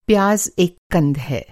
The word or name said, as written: कंद